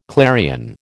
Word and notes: clarion: American English

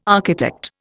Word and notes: architect: Indian

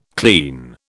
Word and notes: clean: British English

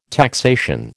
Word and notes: taxation: American English

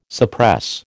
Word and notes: suppress: American English

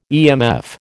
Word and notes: EMF: American English

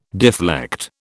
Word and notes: deflect: British English

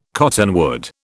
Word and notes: cottonwood: British English

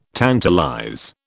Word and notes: tantalize: British English

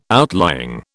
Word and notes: outlying: British English

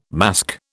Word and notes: masque: British English

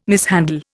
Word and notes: mishandle: Indian

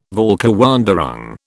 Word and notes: Völkerwanderung: British English

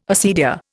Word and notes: acedia: Indian

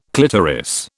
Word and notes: clitoris: British English

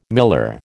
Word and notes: miller: American English

